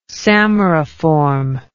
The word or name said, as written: samariform